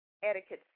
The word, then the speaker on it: etiquette: And once you pronounce etiquette slowly, you actually hear yourself say ticket. Etiquette. So the Q actually does, in this case, make the sound of the K.